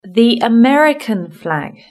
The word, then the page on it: the American flag: British English